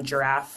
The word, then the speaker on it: Jirav: And the last tool that we've implemented that I really liked is Giraffe for FP&A, and it's spelled J-I-R-A-V.